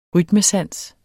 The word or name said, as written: rytmesans